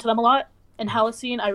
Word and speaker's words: Halocene: Glory, can you pronounce that name for me? – Halocene. – Halocene. Okay, thank you, Ryan.